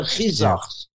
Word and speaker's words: Kazakh: Those are the Khizakhs. – Okay. – We pronounce it Khizakh, but in Latin or any other language, okay, they don't have that sound. So the kh becomes k.